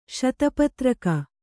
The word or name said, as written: ಶತಪತ್ರಕ